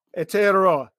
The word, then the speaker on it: Aotearoa: I even watched the video, mate, to get it right, and I still butchered it. – Aotearoa. – Aotearoa. Okay. I didn't even get it right.